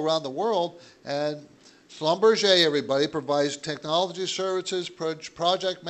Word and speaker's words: Schlumberger: Schlumberger. That's how you say it, even though it looks like Schlumberger. Schlumberger is Schlumberger.